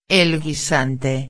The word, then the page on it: el guisante: espanhol